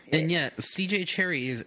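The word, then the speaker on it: Cherryh: She appended a silent H to her real name because her first editor felt that Cherry sounded too much like a romance writer.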